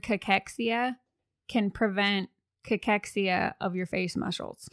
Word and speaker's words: cachexia: So the phonetic pronunciation is cachexia. So cachexia. – Cachexia. – It comes from the Greek word kakos.